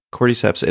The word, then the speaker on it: cordyceps: Is that how you pronounce it? Yeah, cordyceps.